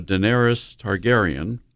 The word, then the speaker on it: Daenerys Targaryen: So Daenerys Targaryen, I pronounced that. – Mm-hmm. Yep, that's right.